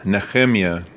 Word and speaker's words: Nehemiah: We are in Nehemiah is the actual way to pronounce it.